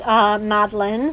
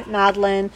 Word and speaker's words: Madeleine: Yeah, so my full name is Claire Madeline O'Hagan, and it's spelt like Madeline, like M-A-D-E-L-E-I-N-E, the French way.